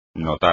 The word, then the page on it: notar: espagnol